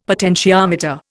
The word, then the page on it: potentiometer: Indian